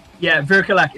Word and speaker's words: Vircolac: It's actually Vircolac. It's actually a Romanian word, so I believe we aren't even pronouncing it 100% correctly. So we say it phonetically, Vircolac.